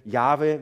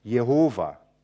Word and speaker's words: Jahwe: Und eine Zeit lang wurde Jahwe wie ausgesprochen? Jehova. Und die spätere Forschung hat eigentlich rausgekriegt, das ist die falsche Leseart.